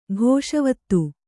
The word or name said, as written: ಘೋಷವತ್ತು